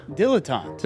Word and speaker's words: dilettante: Dilettante. – There's two T's there. You sure it's just dilettante? – I am sure.